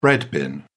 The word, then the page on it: bread-bin: British English